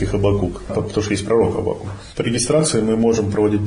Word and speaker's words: Аввакум: Так произносится Аввакум? – Да. – Я почему-то думал Аввакум. – Аввакум – это по литературному на русском, да, Аввакум.